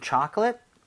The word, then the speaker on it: chocolate: Chocolate 할 때는 앞에 O의 강세가 가죠. 그러면서 입 모양이 좀 벌려지겠죠. – 예. – So, 초콜릿보다는 초콜릿에 가깝고요.